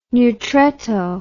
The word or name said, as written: neutretto